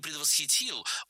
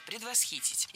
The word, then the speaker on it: предвосхитить: В этом глаголе очень непривычное ударение – «предвосхитить». Именно такое ударение считается литературной нормой, и другие варианты не предусмотрены. Поэтому «предвосхитить», «предвосхитил», «предвосхитим» и так далее. Всегда ставим ударение на третий слог.